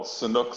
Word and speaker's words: Sanuk: But before we begin, is it true that you ran a 5K in Sanuk sandals? And maybe I'm mispronouncing this, but... – They're called Sanuk sandals. – Sanuk.